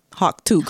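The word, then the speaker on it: Hawk Tuah: So, and Hawk is spelled like Hawk, H-A-W-K, and Tua, even though she said it's Tua, but it's T-U-A-H girl. Hawktua Girl.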